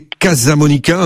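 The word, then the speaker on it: Casamonica: Pour commencer, dites-nous qui est ce clan des Casa Monica, hein, si je prononce bien, dont on a peu entendu parler jusqu'à présent. – Oui, vous avez parfaitement bien prononcé.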